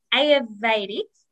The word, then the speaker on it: Ayurvedic: Ayurvedic, is that right? Did I say it right? – Yes.